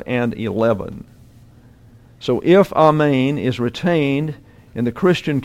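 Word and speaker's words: amen (ἀμήν): You'll note the E has a line over it with an accent. That means it's an eta, not an epsilon. And so an eta gets the sound of a long A in English. So it's amen, amen.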